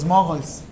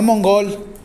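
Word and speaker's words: muggles: Los mogols, se escribe así. Se pronuncia así. No mongol, mogols.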